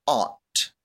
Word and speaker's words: aunt: The first pronunciation given for aunt was aunt, as in my Aunt Terry, as Thurston Howell III might say it.